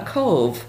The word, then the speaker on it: KOV: So what we did was took kind of a phonetic spelling of the word cove, put the line over the O, which just means the O is pronounced as an O, and that's what Cove is. Cove is just a play on the word cove, C-O-V-E, and because we're situated on a cove where the Passamaquoddy Bay meets the Bocquebec River.